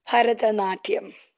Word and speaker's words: Bharatanatyam: And correct me if I'm pronouncing it wrong, but Bharatanatyam, is that the dance form you learned? – Bharatanatyam. – That's the one.